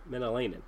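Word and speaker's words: Mäenalanen: The Hurricanes have recalled Sakou Menelin. Menelainen. There we go. I'm looking at it. Menelainen, I believe is how you say it.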